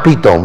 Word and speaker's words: Pithom: I mean, Pithom, it's pronounced Pithom. We say Pithom, but it's Pithom.